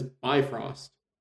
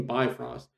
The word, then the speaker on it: Bifrost: It's the way it's read by Will Wheaton, Bifrost. It's actually pronounced Bifrost or Bifrost. It almost sounds like Bifrost.